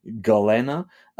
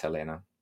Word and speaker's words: Gelena: For you non-Spanish speaking people, if you saw her name, it would be Galena. Galena Solano. It's spelled G-E-L-E-N-A. So for us, that's Helena.